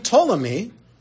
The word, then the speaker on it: Ptolemy: And King Ptolemy, it's PT, so the P is silent.